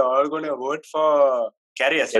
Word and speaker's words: Karius: If we're really looking for an alternate option, I guess we could put up I'm not sure how this is pronounced, Karius. – Karius. – Oh, Karius, yeah.